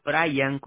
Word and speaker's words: Brayan: B-R-Y-A-N? – Bueno, se escribe Brian con B-R-A-Y-A-N, pero en la tarjeta aparece así como lo mencionaste.